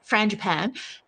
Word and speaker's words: frangipane: Frangipane? Frangipani? – Frangipan. Frangipan.